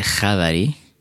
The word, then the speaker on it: Khavari: Euh, comment est-ce qu'on prononce correctement votre nom C'est Kavari.